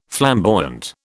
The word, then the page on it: flamboyant: British English